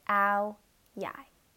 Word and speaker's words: Ao Dai: It's pronounced Ao Dai.